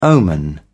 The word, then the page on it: omen: British English